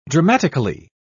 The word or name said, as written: dramatically